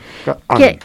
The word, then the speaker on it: Ka'an: Ka'an, no sé cómo se pronuncia. – Ka'an con H, ¿no? Me imagino, o es así es sin H. – Letra K-A, apóstrofe A-N. – Órale, Ka'an.